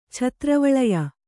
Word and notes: ಛತ್ರವಳಯ: Kannada

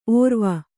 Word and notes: ಔರ್ವ: aurva

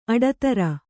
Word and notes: ಅಟ್ಟಿದಿರು: kannada

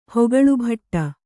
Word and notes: ಹೆಕ್ಕಳಬಡು: hekkaḷabaḍu

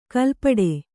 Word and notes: ಕಲ್ಪಡೆ: kalpaḍe

